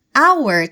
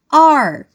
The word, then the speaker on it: our: Our tem duas pronúncias diferentes. Você pode dizer our, que é como eu digo normalmente, ou você pode dizer are, que é como o Ravi diz normalmente.